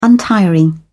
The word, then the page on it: untiring: British English